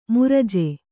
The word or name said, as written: ಮೃಜೆ